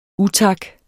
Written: utak

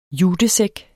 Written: jutesæk

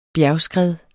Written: bjergskred

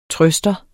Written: trøster

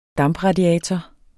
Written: dampradiator

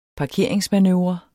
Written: parkeringsmanøvre

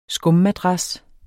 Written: skummadras

